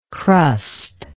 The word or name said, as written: crustless